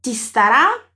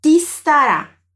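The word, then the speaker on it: ti starà: A partícula ti vai se juntar com o verbo estará e vamos pronunciar como se elas fossem parte de uma única sílaba. Então o ti já vai puxar o som do s para ele emendar tudo. Olha só. Te estará. Te estará. Viu que eu não fiz pausa?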